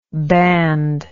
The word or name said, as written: bandless